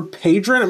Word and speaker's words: Padron: His name was Padron, is the way you say his last name. So it's Nicholas Christopher Padron. It may be Padron, P-A-D-R-O-N, is how you say his last name.